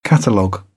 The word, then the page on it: catalogue: British English; t